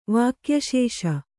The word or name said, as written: ವಾಕ್ಯಶೇಷ